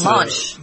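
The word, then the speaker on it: Romansh: And as a result of this, Switzerland has four national languages, French, Italian, German, and something called Romanche, if I said that right. – That's Romanche. – Romanche, yeah. That's in a small canon, I guess they call it, those little political divisions in Switzerland where there is this language there.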